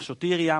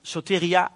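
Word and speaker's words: soteria (σωτηρία): En het woord redding betekent soteria. Ik zag soteria, maar soteria is de juiste klemtoon.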